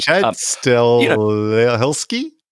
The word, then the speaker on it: Stahelski: I pronounced both of the names terribly. – Chad Stahelski and David Leitch.